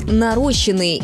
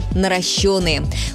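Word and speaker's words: наращённые: Часто можно услышать «нарощенные» или «наращенные». Правильный вариант один – «наращенные». Ударение всегда падает на «ё».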